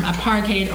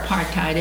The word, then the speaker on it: apartheid: And he uses the term apartheid or apartheid. It's pronounced both ways.